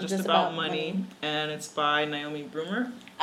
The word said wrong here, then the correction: Bruner: Did I say her name right? – Yeah. – Bruner.